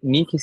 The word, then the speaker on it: niky: É, ele, Nick se escreve N-I-K-Y.